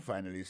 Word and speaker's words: finally: Finally, c'est F-I-N-A-L-L-Y, finally. T'as des prononciations, oui? Finally. Accent tombé sur le premier syllabe là. Finally. F-I-N-A-L-L-Y.